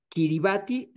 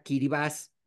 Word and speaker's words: Kiribati: Kiribati realmente se pronuncia Kiribaz de forma local. Esto es una deformación del nombre que dieron los británicos a estas islas Gilbert Islands.